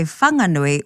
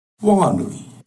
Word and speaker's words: Whanganui: So the H in Whanganui is there, but it's heard as a breath, as they describe it. – In Whanganui, the H is aspirated. So it's not a wha, it's a wha.